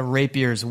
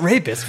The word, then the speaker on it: rapier: Not rapist. Rapier.